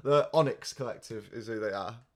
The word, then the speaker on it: Onyx: Um, the tang- the song is called Tango by the Oinks Collective. I'm saying that wrong. O N Y X. It's like a fucking metal or something, isn't it? Onyx. Onyx. Okay.